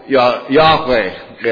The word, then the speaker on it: Yahweh: The Yah, and that draws us to Yahweh, is probably is a better pronunciation even of that than our English transliteration that we use so often.